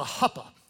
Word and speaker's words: chuppah: they would go into what was called a huppah. It's spelled C-H-U-P-P-A-H.